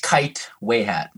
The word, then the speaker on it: Kytwayhat: You're actually really close. – Okay. – Kytwayhat is close. It's Kytwayhat.